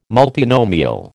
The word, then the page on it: multinomial: American English